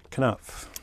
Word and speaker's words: Knopf: Knopf.